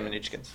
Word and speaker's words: Mnuchkins: Mnuchkins. Mnuch. Like Munchkins, but Mnuchkins.